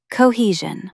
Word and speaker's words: Cohesion: Put the stress on the middle syllable. Cohesion. Try saying it. Cohesion.